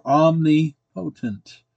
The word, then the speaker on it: omnipotent: Number one, God is all-powerful or omnipotent. That's how it's spelled. Omnipotent is how it's pronounced.